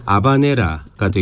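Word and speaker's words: habanera: 이 뭐 스페인식 원어 발음으로는 H가 묵음이니까 아바네라가 되겠습니다만 그렇죠.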